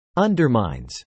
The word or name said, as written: undermines